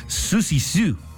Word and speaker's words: Siouxsie Sioux: Se pronuncia, bueno, perdóneme usted, Susie Sue.